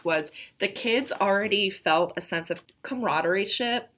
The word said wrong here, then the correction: camaraderie: Camaraderie? – Ship. – Okay, so no ship, just camaraderie.